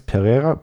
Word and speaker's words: Pereira: Das ist Alex Pereira, also ein bisschen das R wird auf Portugiesisch mit H ausgesprochen, gegen Israel Adesanya.